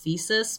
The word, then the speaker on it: Physis: Where he learns from Soldier Blue and this blind oracle they have named Physis, who, looking at the katakana, her name is actually supposed to be pronounced Physis, but I'm not doing that.